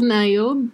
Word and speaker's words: Nao: Pronounced as Nayo, but her name is N-A-O.